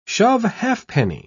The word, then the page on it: shove-halfpenny: British English